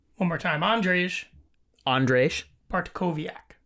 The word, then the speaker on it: Bartkowiak: Andrzej Bartkowiak.